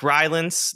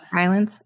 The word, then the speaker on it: Rylance: And then Mark Rylance. Did I say that right? Rylance? Mm-hmm. I had to have. – Yeah. Rylance.